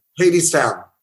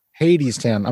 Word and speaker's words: Hadestown: So is it pronounced Hadestown or Hadestown? How do you say it? – Hadestown. – Hadestown. I'm an idiot.